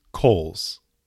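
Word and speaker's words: Cowles: It is Coles. – Coles. – Yeah. Rhymes with Knowles.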